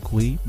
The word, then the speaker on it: Cui: And of course, you could find me, Brian Kui. My last name is spelled C-U-I on LinkedIn.